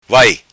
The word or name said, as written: vai